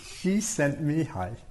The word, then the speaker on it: Csikszentmihalyi: Mihaly, Csikszentmihalyi. Oh, thank you. Say it, please. She sent me high.